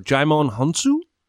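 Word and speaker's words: Djimon Hounsou: And then I also wrote down, and I'm going to butcher this name because I screwed it up in the Sins video, Jaimon Hunsu. – Oh, nice. Yeah. – Sounds good to me.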